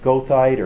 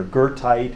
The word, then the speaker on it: goethite: It's called goethite or goethite, if you want to use the German pronunciation.